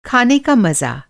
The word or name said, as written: मज़ा